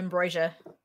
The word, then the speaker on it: Ambrosia: Her name is Ambrosia or Ambrosius. I don't know how you pronounce it exactly. – Ambrosia.